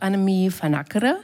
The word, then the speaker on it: Annemie van Ackere: Bei uns ist immer noch Annemie van Ackere. Wie spricht man eigentlich deinen Namen richtig aus? – Du hast das perfekt gemacht.